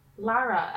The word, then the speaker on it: Lara: Lara, L-A-R-A.